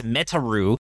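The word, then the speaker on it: Metaru (メタル): The second word makes more sense in Japanese since the English word metal is pronounced Metaru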